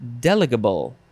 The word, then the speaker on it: delegable: But the emphasis is on the first syllable. Delegable.